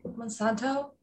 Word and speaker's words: Monsanto: Am I saying that right? Monsanto? Yeah.